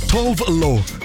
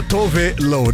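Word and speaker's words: Tove Lo: А даже есть кто называет ее Товло. – Товло, я тоже так называла. Как я только не коверкала. Я еще люблю это. – Шведка, да, Товело, да, вот так произносится.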